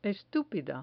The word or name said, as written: estupida